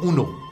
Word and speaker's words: un: Voy a pedir a usted de prestar atención sobre la pronunciación del número uno. Je dis un. Je répète. Un. El sonido viene de la nariz, porque el francés es mucho de la nariz.